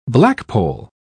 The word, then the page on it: blackpoll: British English